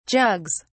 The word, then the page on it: jugs: British English